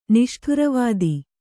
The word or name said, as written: ನಿಷ್ಠೂರವಾದಿ